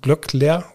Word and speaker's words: Glöckler: Doppelgänger Philipp Glöck, na, Glöckler, so ist es richtig ausgesprochen, ähm, so ein bisschen in Deutschland losgetreten worden sind.